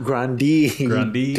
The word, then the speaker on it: Grande: Say it right. We learned how to say her last name right. – Oh, Ariana Grande. – Grande. Dipshit.